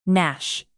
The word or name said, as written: gnash